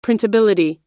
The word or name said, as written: printability